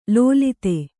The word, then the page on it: ಲೋಲಿತೆ: lōlite